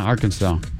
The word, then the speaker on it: Arkansas: Arkansas is the hardest state for Americans to pronounce. – Arkansas? – Yeah, Arkansas. People just won't say saw. They'll go sass or sizz or whatever it is.